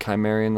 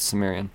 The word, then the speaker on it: Sumerian: We've got Sumerian, which I learned that I've been pronouncing it Chimerian the whole time. It's Sumerian.